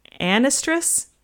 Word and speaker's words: anestrus: So it's like estrus, but with a n in front of it. An estrus. An estrus.